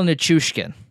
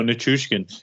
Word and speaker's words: Nichushkin: Just a well-rounded guy, kind of like Nikushkin. I can't. Whenever it comes out of my mouth, I completely butcher it. – Val Nichushkin. – There you go, Nichushkin.